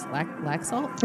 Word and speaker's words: Laxalt: Governor I should know how to say this. Laxalt? – Yes, yeah, it's your home state now.